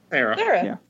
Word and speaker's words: Cera: Sweet Chuck would be Michael Sarah. Sarah? How do you say it? C-E-R-A? – Sarah.